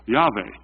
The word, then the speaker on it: Yahweh: Yahweh is one way to think about pronouncing it. It might not be the way you pronounce it because there's a mystery to how you pronounce God's name. But those are the letters, those are the consonants of God's name. Yud-Heh-Vav-Heh, often pronounced Yahweh.